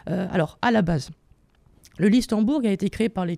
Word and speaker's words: Listembourg: Sur Twitter est né un nouveau pays, le Listembourg, je ne sais même pas si je le prononce bien. – C'est ça.